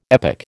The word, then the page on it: epic: American English